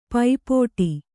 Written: ಪಾಪಟಿ

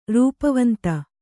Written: ರೂಪವಂತ

